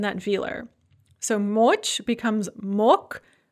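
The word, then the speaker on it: mok (мог): So note that on mok, the g is pronounced like a ka because of word final devoicing, but we add the feminine ending ana magla, the g is pronounced as a g once again.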